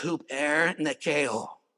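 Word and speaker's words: hupernikeo (ὑπερνικάω): I even helped you pronounce it right there. It's hupernikeo. Hupernikeo.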